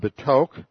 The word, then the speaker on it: betok: based on the Hebrew term betok, and that is spelled B, and this is a very short E, it's a Shiva in the Hebrew, B-E-T-O-K, and means in the midst or among.